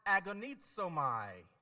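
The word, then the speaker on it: agonizomai (ἀγωνίζομαι): It's pronounced agonizomai. Agonizomai.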